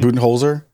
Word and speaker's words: Budenholzer: Budenholzer? – No, it was right.